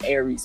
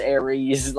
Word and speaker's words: Eryxse: CTW, it's Eryxse, and you pronounce it like the damn astrological sign or whatever, so it's like Aries.